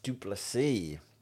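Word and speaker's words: du Plessis: Dricus Duplessis. The S is silent.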